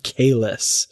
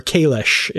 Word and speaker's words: Kahless: But at the end of this issue, Chancellor Laurel is captured by these forces who are calling themselves the Shadows of Kalis. Or Kalish, if you're Klingon. – Going by the proper pronunciation according to the anyway.